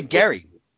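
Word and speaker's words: Gerry: And he, his last name was Jerry. – It was actually Gary. – Gary, was it pronounced Gary? I'm sorry, I just read it. – No, it's actually one of those things, where we should be saying gerrymandering all this time.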